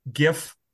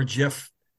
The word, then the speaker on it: GIF: That's my, that's my, you guys can have whatever GIF or GIF, famous GIF reaction you want. – I'm still a hard G on that one.